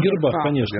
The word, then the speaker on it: гербах: Как правильно сказать по-русски? Гербах или о гербах? – Гербах, конечно. Гербах, гербах, да. Грибах. Вот, это, нет, да, серьезно. – Согласен. – Почему-то здесь плавает ударение, но гербах, конечно.